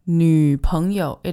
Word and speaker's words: 女朋友: Now be very careful with the pronunciation of this. 女, purse your lips, people. 女朋友. It is not 女朋友. It's not a 女, it's a 女, 女朋友.